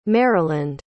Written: maryland

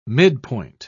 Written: midpoint

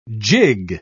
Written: jig

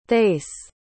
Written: thaïs

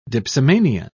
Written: dipsomania

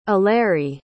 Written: eleri

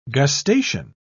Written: gustation

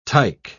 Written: tike

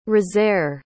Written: rosaire

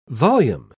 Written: volume

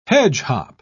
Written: hedgehop

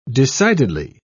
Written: decidedly

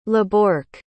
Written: laborc

